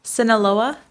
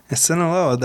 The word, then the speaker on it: Sinaloa: She was 21 years old and had recently moved to Arizona from, I know how to say this, Sinaloa, Mexico. – Sinaloa, that's where we just were when we visited Mazatlan.